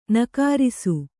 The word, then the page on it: ನಕಾರಿಸು: nakārisu